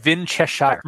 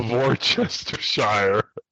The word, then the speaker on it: Worcestershire: Or the Winchestershire. – No. The Worcestershire. – God. – And no. Nobody pronounces it right.